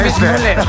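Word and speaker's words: Weissmuller: Johnny Bismuller. Así lo pronunciábamos. Así lo pronunciamos.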